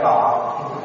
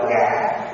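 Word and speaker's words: ngã (我): Cũng giống như trong người Hoa viết là viết ngã, nhưng mà nói chuyện hàng ngày là nói ngọ, nị với ngọ đó. Nhưng mà khi viết ra chữ thì viết ngã, cho nên trong văn chương chúng ta phải đọc là ngã,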